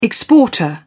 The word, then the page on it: exporter: British English